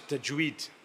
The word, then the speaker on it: tajweed: By the way, that's the correct recitation and the correct pronunciation within the Quran. The art of it is known as tajweed. That's why we say tajweed.